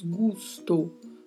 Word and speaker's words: gusto: Na pronúncia, a letra O é mais fechada, então falamos gusto, gusto.